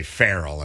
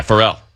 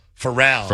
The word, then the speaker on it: Pharrell: Pharrell, is that how you say it? I used to say Farrell, and it's wrong. – Yeah, Pharrell. – Pharrell.